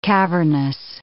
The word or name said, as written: cavernous